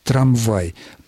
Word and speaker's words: трамвай: Аналогичную замену мы наблюдаем, например, в слове «трамвай». Правильное произношение «трамвай», так как я только что произнес, но мы знаем, что существует просторечный вариант «трамвай».